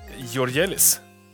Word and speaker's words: Georgelis: Denna vecka säger jag också hjärtligt välkommen till Nikos Georgielis.